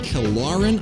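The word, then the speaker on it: Kilauren: April 2nd, 1997, Joni Mitchell is reunited with Killaren, I think is how you pronounce it. – That's right. – Killaren Gibb, a daughter she had given up for adoption 32 years before, and, you know, God bless you for doing it.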